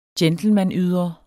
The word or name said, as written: gentlemanydre